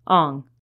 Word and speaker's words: Ong: Ong. Ong, spelled O-N-G, is basically the same as Wang or Ng, spelled N-G, which is the Cantonese pronunciation.